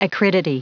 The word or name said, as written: acridity